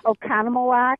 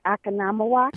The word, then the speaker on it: Oconomowoc: Oconomowoc.